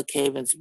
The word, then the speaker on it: Cavins: My name is Ayodele Cavins Branch. I would clearly have to spell that for you, Ayodele. C-A-V-I-N-S, last name Branch, like a tree.